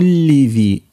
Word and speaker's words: لذي: قسم لذي، لا تنطق النون.